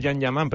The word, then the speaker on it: Can Yaman: ¿Man Kayan? – ¿Cómo era? Se pronuncia Can Yaman, pero bueno, es que estoy viendo aquí el vídeo.